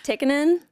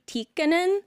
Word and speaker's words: Tikkanen: So that's like the Americanized way, but apparently when it's Finnish, which is, that's where I'm from, where my dad's from 100%, you have to pronounce both consonants. So it's technically Tikkanen,